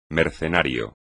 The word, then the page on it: mercenario: Spanish